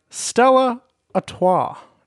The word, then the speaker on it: Stella Artois: Dan's Sports Corner, brought to you by Stella Artois.